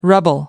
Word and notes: rubble: American English

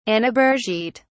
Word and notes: annabergite: British English

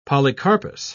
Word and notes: polycarpous: British English

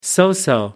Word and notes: so-so: American English

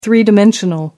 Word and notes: three-dimensional: American English

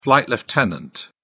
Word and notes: flight lieutenant: British English

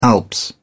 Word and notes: the Alps: British English

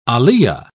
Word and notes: aliyah: British English

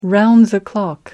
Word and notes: round the clock: American English

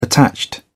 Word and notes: attached: British English

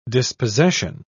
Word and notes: dispossession: British English

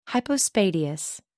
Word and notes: hypospadias: American English

